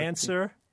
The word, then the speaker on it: answer: Or answer. – W.